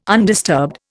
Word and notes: undisturbed: Indian

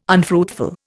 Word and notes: unfruitful: Indian

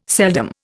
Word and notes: seldom: Indian

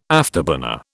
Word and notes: afterburner: British English

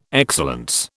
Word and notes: excellence: British English

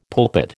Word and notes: pulpit: American English

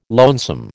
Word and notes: lonesome: American English

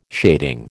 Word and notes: shading: American English

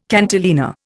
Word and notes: cantilena: Indian

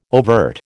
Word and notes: overt: American English

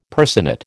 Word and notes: personate: American English

